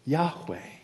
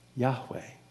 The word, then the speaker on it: Yahweh: and that is Yahweh. Yahweh. At least that's the way we pronounce it now.